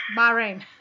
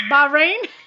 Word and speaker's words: Bahrain: Bahrain.